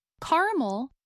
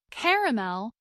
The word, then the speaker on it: caramel: Caramel, two syllables, or caramel, three syllables, is a light brown candy made from butter, sugar, and milk. In much of the Northeast and parts of the South, this word is pronounced with three syllables, caramel. In parts of the Appalachian Mountains and much of the Midwest, many Americans pronounce this word with two syllables, as in caramel.